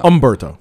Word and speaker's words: Humberto: Dude, you can't pronounce the H. I've been telling you that for weeks now. – Humberto.